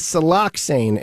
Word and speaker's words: siloxane: We refer to it as siloxane, and I'll spell it for you. It's S-I-L-O-X-A-N-E.